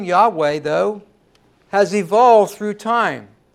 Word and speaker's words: Yahweh: The Lord's proper name is a combination of four letters, four capital letters, YAWH. We pronounce it Yahweh.